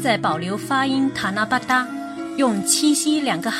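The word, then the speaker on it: たなばた: 古时日本有神事活动塔那巴达，写成汉字就是棚屋的棚和机器的机，日语发音是塔那巴达。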